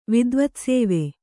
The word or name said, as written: ವಿದ್ವತ್ಸೇವೆ